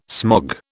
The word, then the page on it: smog: British English